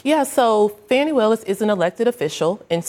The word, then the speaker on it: Fani Willis: Uh, the prosecutor, uh, Fani Willis, is how you pronounce mm-hmm.